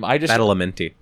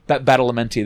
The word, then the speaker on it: Badalamenti: It's almost like Angelo Baldamati for... – It totally is. – Also, I know I butchered his last name. – Badalamenti. – Badalamenti, there we go.